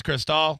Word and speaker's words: Cristol: I'm assuming it's Cristol. – Yes, you're one of the few that are pronouncing it the right way, man.